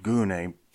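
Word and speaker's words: gune (γυνή): Now that the pronunciation of that Greek word, gune, sounds a little derogatory. It's just how you pronounce that in Greek. We would say gyne, G-Y-N-E, as in a gynecologist.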